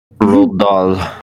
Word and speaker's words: Roald Dahl: Roald Dahl. – Roald Dahl. I want to call him Ronald, but it's not.